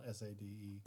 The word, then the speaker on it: Sade: I love Sade, not Sade. – Well, it's spelled S A D E. – It's Sade, and she's awesome.